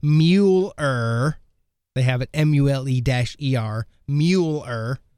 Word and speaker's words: Mueller: It's pronounced Mueller. Like Mueller Light, duh.